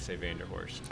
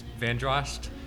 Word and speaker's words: Vanderhorst: And I've heard that the correct pronunciation is Vandrost, but I've never heard anyone say it that way.